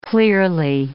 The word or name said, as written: clearly